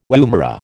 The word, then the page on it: woomera: American English